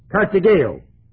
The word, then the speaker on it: katargeo: Kartageo is the word. K-A-T-A-R-G-E-O. Long E and long O. Eta and Omega.